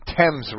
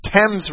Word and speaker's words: Thames: On, uh, I was corrected tonight. It's the Thames River. I see an, a th and an a, and the English see a te. I, I, I guess it's their river. I'll pronounce it the way they do.